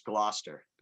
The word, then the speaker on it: Gloucester: Yeah, there's no back and forth. It's Gloucester.